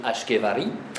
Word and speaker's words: Ishkavari: Alors Qutb ad-Din naquit dans le village d'Ishkavar, d'où son nom d'Ishkavari, prononcé aussi Ashkevari.